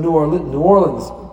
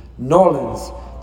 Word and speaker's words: New Orleans: They were in New Orleans, apparently I mispronounced it. New Orleans.